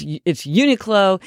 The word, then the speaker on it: Uniqlo: So this is the Uniqlo Ultralight Down Vest is my hack, and I had to look up how to pronounce Uniqlo because I've been mispronouncing it all these years, but it's Uniqlo,